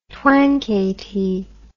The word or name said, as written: twankay tea